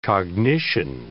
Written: cognition

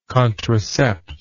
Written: contracept

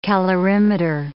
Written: calorimeter

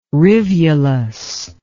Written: rivulus

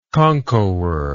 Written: conchobar

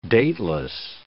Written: dateless

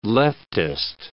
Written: leftist

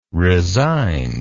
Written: resigned

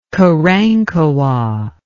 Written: Karankawa

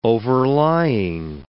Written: overlying

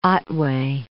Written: Otway

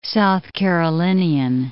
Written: South Carolinian

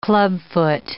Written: clubfoot

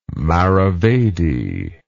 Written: maravedi

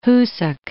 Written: Hoosac Mountains

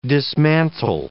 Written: dismantle

